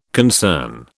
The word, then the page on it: concern: British English